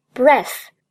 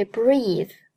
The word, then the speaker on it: breathe: 最后呼吸的动词注意不是 breath，因为 breath 是名词的呼吸，动词的呼吸应该读作 breathe，breathe。所以整句连起来，You look up to the light and you feel how suddenly it's easier to breathe。